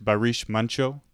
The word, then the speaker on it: Barış Manço: Is that correct, Barış Manço? Yes, that's correct.